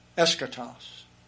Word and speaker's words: Eschatos: Eschatos. Eschatos is the British pronunciation.